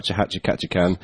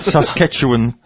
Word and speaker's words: Saskatchewan: I'm a network administrator / computer technician for a rural school district in Saskatchewan, Hatcha Catchacan, Canada. – That's not how you pronounce it, is it? – Saskatchewan, Canada.